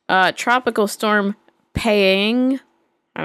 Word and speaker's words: Paeng: I kind of read it as pain, but it's got a G at the end. Pang. Oh. – Pang Mang. – Yeah, Pang.